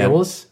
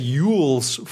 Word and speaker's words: Elwes: I said Yules for like 10 years because I just thought that's got to be how you say it. – Well, you're wrong about that, but you're right about this game.